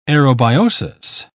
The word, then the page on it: aerobiosis: British English